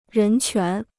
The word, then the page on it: 人权: rén quán